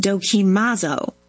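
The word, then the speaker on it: dokimazo (δοκιμάζω): The Greek verb Paul uses for test is pronounced dokimazo.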